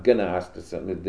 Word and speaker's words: Gnosticism: And Gnosticism, it's spelled with the word, it's G-N-O-S-T-I-S-M something. Starts with, it's not Gnosticism, the G is silent.